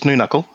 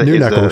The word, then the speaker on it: Noonuccal: Did I say that right? – Oh, it's, it's, it's Noonuccal. – It's a, Noonuccal, sorry. I wasn't sure how to pronounce New it. Knuckle, yeah.